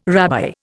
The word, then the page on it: rabbi: Indian